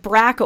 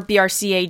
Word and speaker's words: BRCA: It's like screaming from the rooftops that, you know, the BRCA or BRCA gene, which can be pronounced either way, in case that's confused people before now.